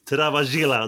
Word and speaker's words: Travaglia: Han möter hemmasonen Travaglia, Travaglia, Travaglia kanske det ska heta, italienare. Travaglia i första. – Så du säger om det uttalet på klockren italienska.